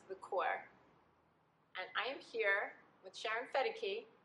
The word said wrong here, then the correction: Fekete: Or Fekete. And nobody can say my name. I've been saying it wrong the whole time.